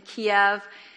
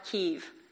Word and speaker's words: Kyiv: And we were stationed, our hotel and stuff was in Kiev. That's the Russian pronunciation for it. The Ukrainian people call it Kyiv.